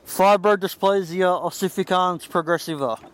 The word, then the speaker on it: Fibrodysplasia ossificans progressiva: Fibrodysplasia ossificans progressiva.